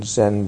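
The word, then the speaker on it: Zen (全): The Zen in Zenki is not the same as the Zen in Zen Buddhism or Soto Zen or Zazen. It's a different character that's pronounced Zen. It means complete or total or whole or everything.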